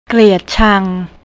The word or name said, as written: kliatchang